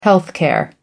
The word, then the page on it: health care: British English